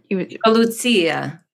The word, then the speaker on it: Lucia: What was the old fashioned way? It wasn't Lacey. There was some other. – Lucia. – Oh, Lucia.